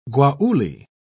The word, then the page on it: guayule: British English